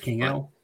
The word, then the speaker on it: Vikingo: I watched the Viking Del Hinga. I can't pronounce his name to save my life. – Vikingo? – Yeah, I watched his match.